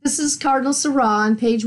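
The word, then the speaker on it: Sarah: So let's go on to what Cardinal Seurat, rhymes with hurrah, as I say, says about the liturgy in the context of the crisis of the Church.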